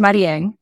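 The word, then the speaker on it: Marién: And yeah, my name is Marien. There's an accent over the E.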